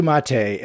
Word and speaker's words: MATE: Ubuntu Mate, and you pronounce it mate. I was pronouncing it mate. I think it is officially pronounced mate because it's the name comes from the yerba mate plant, so which is pronounced mate, okay.